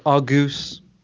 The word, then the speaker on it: Argos: It's pronounced Argus.